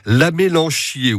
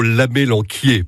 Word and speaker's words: amélanchier: Philippe Guiton est toujours les meilleurs spécialistes pour découvrir l'amélanchier, ou plutôt l'amélanquier, pour bien prononcer, des baies comestibles.